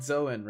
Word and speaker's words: Dzoan: how do you say his last name? It's like, yeah, it's like Zoan, right? Like D-Z-O-A-N.